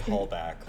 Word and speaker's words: Halbach: I say Halbach, like callback.